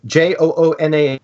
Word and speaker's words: Joonas: But Jonas, and I want to get his name right, Joonas.